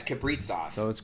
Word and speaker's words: Kaprizov: Because you said it like Kaprizov, right? – Yeah, it's Kaprizov. – So Capri Sun. – Kirill Kaprizov.